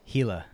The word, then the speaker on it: Gila: Say, pronounce it one more time for me. – Gila. – Gila. I don't know why they not put a fucking H.